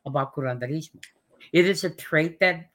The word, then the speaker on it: Curanderismo: So you're a teacher of Curanderismo, and I know I don't say that right, of the Texas School of Curanderismo.